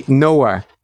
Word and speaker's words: Knauer: So you pronounce the Knauer. I'm going to say Knauer. And that is what an entitled surname. Knauer. As if you are the person who get to decide who knows and who doesn't. Trenton. So that's Trenton's problem. His surname, if it's mispronounced, sounds like Noah.